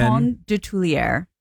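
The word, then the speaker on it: Coton de Tulear: C-O-T-O-N. – Coton? – C-O-T-O-N. – Coton de Tulier. – How the hell do you spell that? – Of Tulier. D-E-T-U-L-E-A-R.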